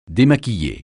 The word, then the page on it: démaquiller: français